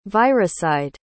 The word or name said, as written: viricide